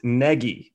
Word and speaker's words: Nagy: Please, everyone, especially sportscasters, stop calling the new coach Nagy. He told the Sun Times it's pronounced Nagy.